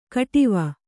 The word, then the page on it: ಖಟ್ವ: Kannada